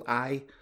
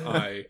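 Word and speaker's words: Ay: And so he's going to be like managed and manipulated by an, this advisor called he's called I. Uh, it's A Y. I think it's just pronounced I.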